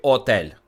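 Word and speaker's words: hôtel: Готель в радянських словниках є готель, а не хотель чи отель. Він готель, тому що в оригіналі там Г. Хоча, здається, вимовляється це як отел. Ну там з'їдається той перший звук.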